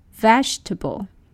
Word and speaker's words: vegetable: People tend to pronounce this word with four syllables, vegetable. But actually, native speakers would say vegetable. Two syllables, vegetable.